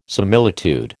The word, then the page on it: similitude: American English